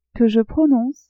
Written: que je prononce

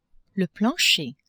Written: plancher

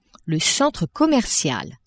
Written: centre commercial

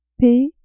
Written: p

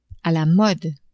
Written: a la mode

